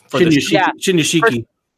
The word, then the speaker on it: Shinyashiki: That new, the young kid rookie, Shinyashiki. – Close enough. – Shinyashiki. – Even better for this show.